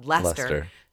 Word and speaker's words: Leicester: But I actually saw a YouTube video that told me it's spelled Leicester.